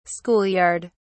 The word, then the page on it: school yard: British English